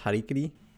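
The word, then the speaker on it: Harakiri: I think the current consensus is it's pronounced Harikiri.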